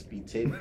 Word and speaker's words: B Tidy: It's spelled Be Tidy, but it's Be Titty.